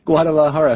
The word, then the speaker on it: Guadalajara: Guadalajara. So when you see that J in Spanish words, that has an H sound.